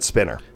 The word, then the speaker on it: Spiner: Very happy birthday to Data, 74 years young for Brent Spiner. – That's actually pronounced Spiner, but yes.